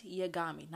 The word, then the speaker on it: Yagami: Light Yagami, not Bigami, Yagami, Light Yagami. So Death Note is about Light Yagami.